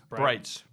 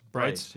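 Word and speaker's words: Breitz: It's Brights. – Brights, yeah. – And that's B-R-E-I-T-Z, exclamation point for anybody.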